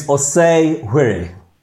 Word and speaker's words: Osei-hwere: So, yeah, so it's, it's Osei Hwere.